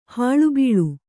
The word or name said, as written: ಹುಲಿಬಲೆ